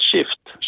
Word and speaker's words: Shft: Ja, den heter då Shift, S-H-F-T, uttalas Shift.